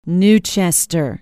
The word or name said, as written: new chester